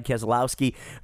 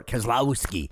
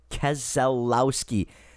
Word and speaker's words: Keselowski: By the way, did you notice how I pronounce his last name? Keselowski. When his family was running trucks back in the 90s and early 2000s, it was pronounced correctly then. Now, listen to the guys on TV. They all slur Keselowski. It's four syllables, gang, not three.